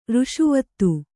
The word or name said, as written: ಋಷಿವಧು